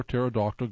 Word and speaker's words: pterodactyl: Why can't you hear a pterodactyl go to the bathroom? Because the P is silent.